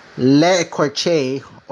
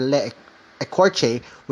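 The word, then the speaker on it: l'écorché: The title is a French word pronounced le corche or le ecorche, which means flakes, there was a cool scene where they find a group of inactive hosts stored like brand new toys.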